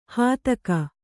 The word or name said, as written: ಹೆತ್ತೇಗ